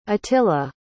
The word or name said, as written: atilla